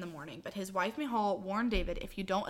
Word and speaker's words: Michal: Google says that it's Michal.